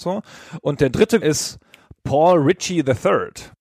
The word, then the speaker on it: Reiche: Genau so wird er auch ausgesprochen. – Ja, genau. Ich habe es vorher nachgeschaut. Ich bin ja nicht bescheuert. Ich habe nämlich jahrelang Paul Reiche III gesagt, bis mir irgendwann aufgefallen ist, dass es nicht geht.